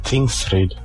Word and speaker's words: Tingsryd: Wie spricht man hier Tingsdingsbums aus? – Tingsryd. – Was?